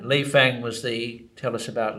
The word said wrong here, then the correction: Lei Feng: Lei Feng, his, yeah, in Chinese pronunciation, the Lei Feng.